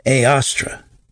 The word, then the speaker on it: Eostre: The assignation of Easter, aka the day of Ishtar, is derived from the alternate name for the goddess Ostara, also spelled E O S T R E and pronounced Aostra, known also in the pagan worship of some nations as Ishtar and in others as Isis.